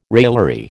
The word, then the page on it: raillery: American English